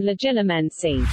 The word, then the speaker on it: Legilimency: And Legilimus? – Fact check. That is not the correct pronunciation, Lauren. These words from the wizarding world of Harry Potter are pronounced Occlumency and Legilimency.